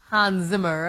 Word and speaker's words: Hans Zimmer: Hans Zimmer.